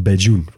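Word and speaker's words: Bajan: Dat spreek je uit als Bejoen, volgens mij.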